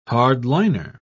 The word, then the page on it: hardliner: British English